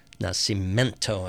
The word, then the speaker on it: Nascimento: And that's a Milton Nascimento tune. Nascimento, I should say. No I in that second to last syllable.